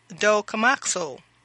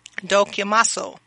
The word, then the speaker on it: dokimazo: So the Greek word is dokimazo. Dokimazo. That's spelled D-O-K-I-M-A-Z-O. Again, D-O-K-I-M-A-Z-O.